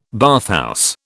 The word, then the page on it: bathhouse: British English